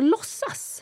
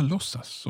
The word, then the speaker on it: låtsas: Ja, and you don't pronounce the T, so låtsas. – Ah, låtsas, okay.